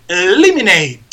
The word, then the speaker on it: eliminate: Por ejemplo, el verbo eliminar se escribe eliminate, eliminate, pero se dice eliminate, eliminate. Esa E no se dice, es muda.